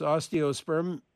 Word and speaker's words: osteospermum: Jean in Rice Lake emailed, ask how she can successfully bring her, how do I pronounce this, osteospermum.